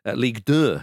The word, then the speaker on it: Ligue 2: Amiens are currently 10th in League 2. Ligue 2, should I say, as we all say Ligue 1, so keep it French.